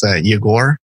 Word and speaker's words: Yegor: It's Yegor, with the emphasis on the O, and Yegor Nadvornyy.